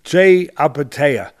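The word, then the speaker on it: Jai Opetaia: I hope I'm saying his name right, Jai Apatea, and I would yeah that's right.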